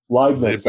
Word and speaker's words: Leibniz: Dick, and you mentioned this too in your notes, as you have what the mathematician Leibniz, am I pronouncing him right? – Leibniz, I believe.